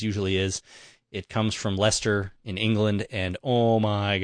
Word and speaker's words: Leicester: L-E-I-C-E-S-T-E-R. – Oh, well, it's pronounced Leicester.